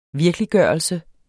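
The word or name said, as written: virkeliggørelse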